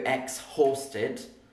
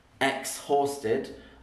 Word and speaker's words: exhausted: And you'll notice I don't really say the H. I don't go exhausted. Exhausted. I say exhausted. So the H isn't really pronounced, and that means it is a silent letter.